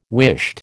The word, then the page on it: whisht: American English